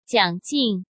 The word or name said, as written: Jiang Jing